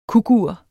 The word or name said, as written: kukur